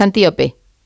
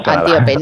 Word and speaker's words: Antíope: lo he dicho mal el nombre, es Antíope. – Antíope, ¿verdad? – Antíope, no, Antíope, no, que la he llamado yo como un animal y no puede ser, Antíope.